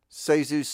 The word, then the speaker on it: Zasu: Sazu's Circus. And it's spelled Z-A-S-U, but it's pronounced Sazu.